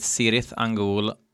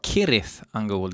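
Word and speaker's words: Cirith Ungol: Bandet uttalade för övrigt Sirith Angol, men egentligen borde det vara Kirith Angol tydligen.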